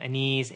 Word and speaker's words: Aniz: I'm also on Twitter at my name, Aniz, A-N-I-Z.